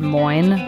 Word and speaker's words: Moin: I don't even know how to pronounce this, but M-O-I-N, Moin, Moin.